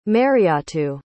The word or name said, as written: mariatu